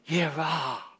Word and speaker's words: Jireh: But the actual word Jireh is exactly the same as what you've just said. Jireh. Jehovah Jireh. It's the same letters. It's just got different pronunciation.